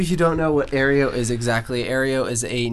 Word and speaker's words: Aereo: Am I saying that right? – Aereo, yes, correct.